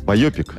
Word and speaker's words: байопик: Байопик. – Байопик. Но вообще, это... на самом деле, ударение на «а».